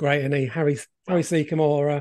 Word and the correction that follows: Secombe: uh, Harry Secombe, as the guy yep.